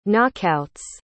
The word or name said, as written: knockouts